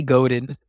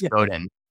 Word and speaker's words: Godin: is it pronounced Seth Godin or Seth Godin? I can never remember. – I always say Godin. – Godin. I think it's Godin.